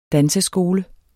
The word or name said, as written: danseskole